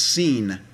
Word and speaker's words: Sin: But the wilderness of sin, by the way, I call it sin, but it's pronounced seen. It's not sin, and it's not to be confused with sin.